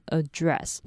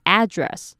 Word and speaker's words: address: 注意当名词使用的时候，你的重音有两种念法，你可以念成 address 或是 address，这两个都有人念。